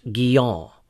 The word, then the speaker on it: Guyon: When I first read the autobiographies of John Bunyan and Madame Guyot, properly pronounced Guyon, I was encouraged by the fact that they too had suffered greatly for their faith in Christ.